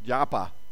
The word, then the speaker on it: Joppa: And I'm not actually sure how that's pronounced. Um, I looked it up on Google, and this voice said Japa. I assume that's the American pronunciation. And then I looked in my Logos Bible, and it said Yope. And I thought Joppa was easier to say, so we'll go, we'll go with that.